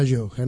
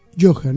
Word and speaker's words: Gioja: Lloja, ¿no? – No, Gioja, con G. – Sí, pero yo se pronuncia en italiano, chicos, sí, ¿no? – Pronuncias así. Claro. – La G ahí se pronuncia yo.